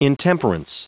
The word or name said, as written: intemperance